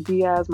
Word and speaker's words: Diaz: Yeah, so you can find me on Instagram, Twitter, Facebook, all social at Grady Diaz. My first name is spelled G-R-E-Y-D-Y, and then Diaz, D-I-A-Z.